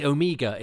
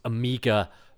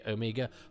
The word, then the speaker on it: Omega: I suspect, like Stephen Hawking's, Omega will become known as Amiga because more people mispronounce it than don't. Now I'm probably going to get letters going, actually, it should be Omega.